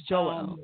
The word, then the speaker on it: Jouelle: Now, first of all, am I pronouncing your name right? Is it Jouelle or is it Jewel? – It's Jouelle.